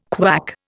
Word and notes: KWAC: Indian

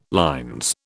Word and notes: LL: British English

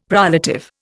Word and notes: prolative: Indian

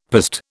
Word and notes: piste: British English

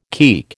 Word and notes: keek: American English

